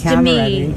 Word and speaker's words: Demi: She's been in the business for 40 years, something like that, and people still having trouble pronouncing her name, because you'd think it was Demi, wouldn't you? But she has gone viral for correcting a fan. – But remember, it's Demi.